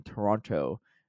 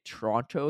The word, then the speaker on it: Toronto: The last T isn't pronounced. Oh, it's funny that this is like the follow up comment. Isn't pronounced in Toronto. Toronto.